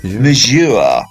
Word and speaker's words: Majeure: How do you pronounce that? Force de Mieux? – Majeure. – You are quite right.